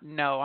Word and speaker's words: Noe: So when you took it and you got in there, first of all, let's tell the listeners a little bit about No. I'm very impressed with the school overall. And you said it's a very elite. It's a high quality school. Tell it's N-O-E is how you spell it.